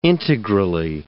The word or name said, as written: integrally